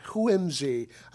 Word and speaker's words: whimsy: Whimsy. I forgot to pronounce the H. – Yes. – The H is important.